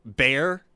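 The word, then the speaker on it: Bexar: Like Bear with an X, B-E-X-A-R, or Refugio, R-E-F-U-G-I-O.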